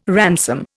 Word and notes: ransom: Indian

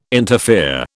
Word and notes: interfere: British English

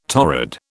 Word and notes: torrid: British English

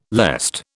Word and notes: lest: British English

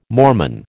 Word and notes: Mormon: American English

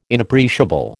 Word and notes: Inappreciable: American English

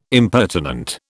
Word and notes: impertinent: British English

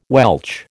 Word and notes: Welch: American English

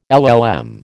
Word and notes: LL M: American English

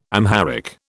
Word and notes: Amharic: British English

